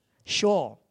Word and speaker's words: Sheol: When they die, they go to this place called, how do you pronounce it? S-H-O-E-L. Sheol.